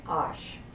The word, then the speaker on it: Osh: And on the back of the lifting is how to phonetically pronounce each name of God. Like Shem, S-H-E-M, Fer, F-E-R, Osh. Shem, Fer, Osh.